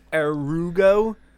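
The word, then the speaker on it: Aerugo: I guess it's pronounced Arugo.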